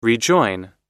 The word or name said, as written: rejoin